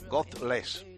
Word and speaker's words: Godless: A ver si lo pronuncio bien. Godless, sin Dios. Godless. ¿Lo he dicho bien o no? Tú que hablas inglés. – Sí, aproximadamente. – Bueno, la ratita se habla. – No, lo has dicho bien.